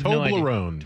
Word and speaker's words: Toblerone: I have Toblerone. – No idea. – Toblerone.